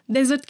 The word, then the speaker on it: desert: Tek S ile olan çöl anlamına geliyor ve onu desert diye söylüyoruz.